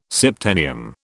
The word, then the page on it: septennium: British English